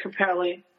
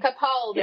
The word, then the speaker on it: Capaldi: And somebody asked him about Peter Capaldi. I said that wrong, but Capaldi. You know what I mean. – Capaldi.